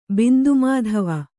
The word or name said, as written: ಬಿಂದುಮಾಧವ